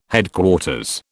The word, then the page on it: Headquarters: British English